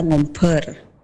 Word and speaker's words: ngombar: Kalau dari tulisan NGOMBAR seperti biasa ngombar. Betul. Ya teman berdua. Tapi kalau dalam pengucapan di bahasa Madura itu ada hanya ngomper.